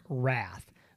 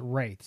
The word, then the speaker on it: Wraith: It looks like Wrath, but it's got an I in it, and it's Wraith.